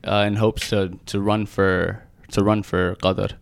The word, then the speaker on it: Qatar: So the Arab pronunciation would be Qatar. – Okay. – But Qatar is probably the closest you can get.